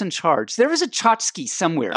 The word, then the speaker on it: tchotchke: And then we talked about a word that was spelled T-C-H-O-T-C-H-K-E. – From my book. – From your book. And it's pronounced tchotchke.